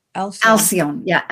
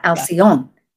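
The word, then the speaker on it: Alcyone: But to have Acelon, right. Am I saying that right? I have trouble with Alcyon. – Alcyon. Yeah, right.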